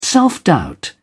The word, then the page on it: self-doubt: British English